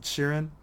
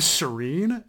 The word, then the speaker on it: Sheeran: Is it Sheeran? – Sheeran. – First time I read it, I was like, his name's Shireen?